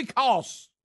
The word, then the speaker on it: Koss: Kobe Koss, is it Koss? Mm-hmm. Kobe Koss, K-O-S-S. You know what that rhymes with, don't you, Kobe? Koss.